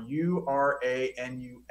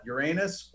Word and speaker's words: Uranus: One of my space facts is there are two correct pronunciations for URANUS. Mm-hmm. Both are completely acceptable. You could call it Uranus or you could call it Uranus. Both of them are acceptable. I changed my pronunciation depending on the maturity of my audience.